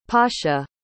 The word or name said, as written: pascha